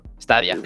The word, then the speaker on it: Stadia: que es el Google Stadia. Creo que sí se pronuncia así, ¿no? Stadia. Ah, bueno.